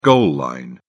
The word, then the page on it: goal line: British English